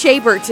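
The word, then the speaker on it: Chabert: Lacey, I think it's Shaybert is how you say it.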